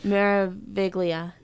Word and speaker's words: Meraviglia: Meraviglia is the one that, I feel like I'm saying that wrong, but Meraviglia, no, that's right.